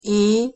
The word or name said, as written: e hosszu